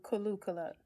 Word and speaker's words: Kaluuya: It's called Kaluuya. – Kaluuya. – Kaluuya. – Like Kaluuya. Hallelujah. Okay, Kaluuya.